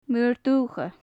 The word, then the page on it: maor-duthcha: Gaelic